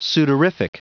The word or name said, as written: sudorific